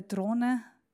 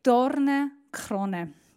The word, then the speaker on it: Dornenkrone: Er wird gekrönt mit der Drohne. Dorn. Das könntet ihr besser aussprechen. Könnt ihr nochmal sagen? Dornenkrone.